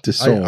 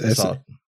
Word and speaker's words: Desaad: Is it Desaad? Desaad? – Desaad. – I don't... – It's Desaad. Yeah, Desaad. It's like A-U-D.